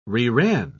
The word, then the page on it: reran: British English